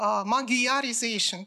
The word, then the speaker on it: magyarization: То есть не случайно в английский язык вошло слово magyarization, да, то есть они не знают, что э, GY э, читается как D, поэтому вот по-английски надо говорить э, magyarization.